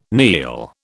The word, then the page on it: kneel: British English